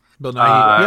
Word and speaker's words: Nighy: Bill Nighy, I think. – Bill Nighy, yeah.